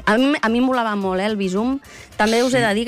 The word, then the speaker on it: Bizum: Doncs mira, jo feia bandera de dir-ne Bizum, així aguda, pronúncia aguda, però la recomanació en aquest cas és Bizum, plana.